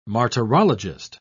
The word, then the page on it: martyrologist: British English